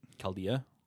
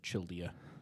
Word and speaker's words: Chaldea: Chaldea. I'm assuming because it's the Chaldeans. – Yes. – Be weird if they pronounced it Childia, but it's the Chaldeans.